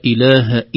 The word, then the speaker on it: إله: فنقول له حقق الهمزة ليست همزة وصل. نقول الله الذي لا إله إلا هو هكذا بالشدة في مخرج الحرف من الحلق الهمزة.